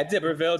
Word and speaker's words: d'Iberville: So first I want to ask you, so why is Pierre Lemoyne d'Iberville, why is it d'Iberville? And I don't know if I'm pronouncing that correctly. Is that correct, the way I'm pronouncing it? – Yeah, d'Iberville. It's okay. Fine, either one.